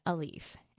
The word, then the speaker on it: Alief: It's a mural painted by the local artist Daniel Quinones with graffiti-style letters at its center spelling Alief, A-L-I-E-F. The Blood Bros grew up in the suburb pronounced Alief, 15 miles southwest of Houston, going to schools where the kids spoke as many as 70 different languages.